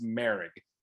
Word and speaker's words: Moehrig: It's spelled Moehrig, Moehrig, but it's pronounced Merrick.